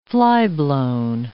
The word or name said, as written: flyblown